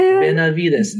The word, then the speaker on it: Benavides: And the way people pronounce the name. And I mean, I got to tell you, to this day, people still can't pronounce my name. I've gotten so used to it that like it doesn't even bother me anymore. Like I've seen every which way you can spell my last name, and I really don't think it's that hard. Like, Benavides.